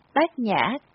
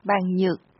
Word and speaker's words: Bát Nhã: Nhìn vào chữ Hán viết là bàn nhược, mà đọc ra âm là Bát Nhã kể cũng lạ. Nếu đọc Maha Bàn Nhược Ba La Mật Đa Tâm Kinh, có lẽ khó nghe hay sao mà quý tổ sư cho đọc là Bát Nhã, còn chữ Phạn thì là Maha Brajana Paramita Sutra.